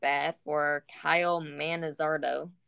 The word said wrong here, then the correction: Manzardo: Manzardo. That's how to pronounce it.